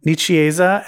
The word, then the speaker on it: Nicieza: Nicieza.